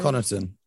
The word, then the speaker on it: Connaughton: Bobby Portis Okay, and Pat m- m- was it McConaughey? McConaughey is that how you pronounce his Pat name? Connaughton.